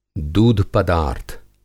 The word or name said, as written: दूध-पदार्थ